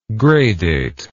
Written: gradate

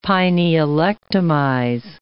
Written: pinealectomize

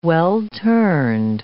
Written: well-turned

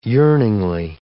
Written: yearningly

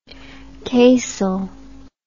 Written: casal

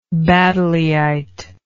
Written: baddeleyite

